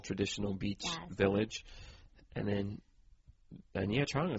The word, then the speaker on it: Nha Trang: Nha Trang? – Nha Trang.